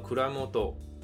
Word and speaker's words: 蔵元: 好，这边提到的汉字写作藏元，念作 kuramoto 啊，指的就是酒造的经营者。